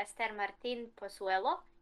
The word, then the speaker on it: Esther Martín-Pozuelo: So yeah, Esther Martin Pozuelo, and yeah, I was actually glad that she got to play, like she played 20 minutes.